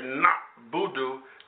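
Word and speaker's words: na'budu (نعبد): So again, it's very important to note that when I say na'budu, there's not a fathah on that ayin, if you know something about Arabic. So if you said na'a, then you have said it incorrectly,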